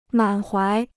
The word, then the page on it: 满怀: mǎn huái